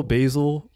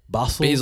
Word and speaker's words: Basel: Art Basel, Basil. – Yeah. – Basel. – Basil is an herb. – spelled wrong. All right. But yeah, what is Art Basel?